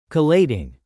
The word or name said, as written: collating